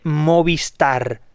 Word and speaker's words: Movistar: Es decir, no debería haber ninguna dificultad de pronunciación para que el español pueda pronunciar correctamente Movistar. Hay una I delante de star, es decir, la regla se cumple perfectamente. Sin embargo, el 99% dice Movistar. No hay una E en la marca Movistar.